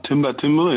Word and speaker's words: Timber Timbre: Spricht man das so aus? – Timber Timbre, ja.